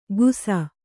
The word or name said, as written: ಗುಸ